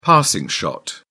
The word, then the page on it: passing shot: British English